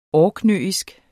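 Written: ORKNØISK

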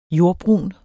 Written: jordbrun

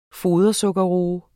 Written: fodersukkerroe

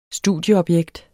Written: studieobjekt

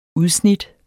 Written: UDSNITTENES

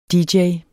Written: dj

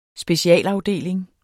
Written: SPECIALAFDELING

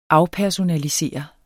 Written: afpersonalisere

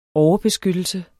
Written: overbeskyttelse

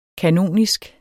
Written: kanonisk

